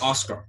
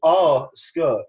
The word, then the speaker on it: Asuka: So, he pronounces it Oscar. – Oscar. – it's Japanese. Like, he says... – It's Korean. – He says, Asuka. – He goes, Asuka, not Oscar. Oscar's a boy's name, mate.